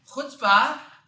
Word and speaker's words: chutzpah: Like just in the chutzpah, it makes it so that chutzpah, which is the correct way to pronounce it, chutzpah, it makes it so that chutzpah is something that is preserved forever.